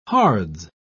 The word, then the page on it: hards: British English